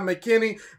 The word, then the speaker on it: McKinney: My name is Jamai McKinney. Name is spelled G-H-I-M-A-N. Last name M-C-K-I-N-N-E-Y.